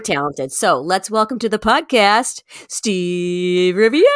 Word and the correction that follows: Rivera: My last name is Rivera, and it's Rivera. Always been, that's always been the case. Adding that extra I. I'm not a car, and I'm not a hotel.